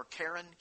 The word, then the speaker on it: Karen: and primarily a group called the Karen people, or Karen, K-A-R-E-N is how it's spelled.